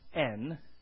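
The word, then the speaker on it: en (ἐν): There's a Greek word that's pronounced en, which is in.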